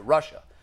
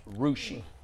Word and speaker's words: Russia: And we were talking about that story last week, and I pronounced it Russia. And we all thought, like, wait, Russia? I've never heard of Russia. That I later found out that it's pronounced Rushi.